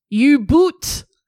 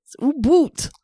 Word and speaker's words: U-Boot: The U-boat, or U-boot. – Fucking what? – That's how you say it in Germany. – Okay, yep. – U-boot.